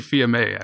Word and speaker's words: Fils-Aimé: Maybe we can get Reggie Fils-Aimé, or is that how you pronounce his name? Fils-Aimé, or? – Reggie Fils-Aimé, actually.